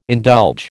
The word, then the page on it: indulge: American English